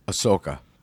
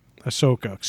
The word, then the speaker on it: Ahsoka: Ahsoka. – Ahsoka. So close. So that H is silent? – Yeah.